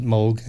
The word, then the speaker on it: Moog: Some people pronounce it Moog, some people pronounce it Moog. My great-grandfather came from Marburg, Germany, so there they pronounce it Moog, and that's what I prefer.